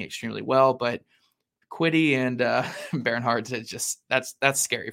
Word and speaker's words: Bernhardt: I researched that. Apparently it's like air. It's like the, it's Bernhardt. – Yeah. It's Bernhardt. Bernhardt is how you say that first name.